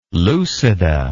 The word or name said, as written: lucida